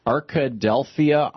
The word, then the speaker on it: Arkadelphia: Jimmy in Arkadelphia, Arkansas. Am I saying that right, Jimmy? – Yeah, you got it right.